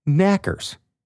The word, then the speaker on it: Knockers: The name Knockers is pronounced Knackers. And I guess if you're saying it in an accent, Tommy Knackers kind of kind of thing comes from the knocking on the mine walls that happens just before a cave-in.